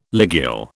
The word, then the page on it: ligule: British English